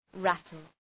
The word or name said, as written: rattle